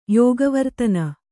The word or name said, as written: ಯೋಗವರ್ತನ